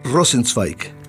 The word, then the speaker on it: Rosenzweig: ¿Cómo se pronuncia? ¿Rosenbay, Rosenway? – Rosenzweig. – Rosenzweig. Eso que es alemán.